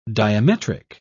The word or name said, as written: diametric